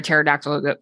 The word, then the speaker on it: pterodactyl: I was like, why can't you hear a pterodactyl go to the bathroom? And you're like, why? I'm like, because the P is silent.